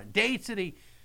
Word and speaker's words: Dade City: Dade City. Am I saying that right, young man? – Well, you are.